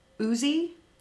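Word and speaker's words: Ötzi: I believe it's Uzi. It's spelled O-T-Z-I.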